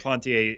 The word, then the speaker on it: Duplantier: Um, but Duplantier, who by the way, we checked with that. That is how you say his name, which is awesome. We did. Duplantier is so fun to say.